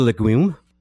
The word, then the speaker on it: legume: And these are lentil, and lentil is a legume. Legume, right? That's how you say it. Legume. Legume.